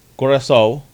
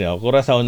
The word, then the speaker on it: Curaçao: Sa group E naman po, you have Germany winning over Curaçao or Curaçao. Curaçao. Dalawa ko si pronunciation niya. Sa American, British, English. Curaçao na lang.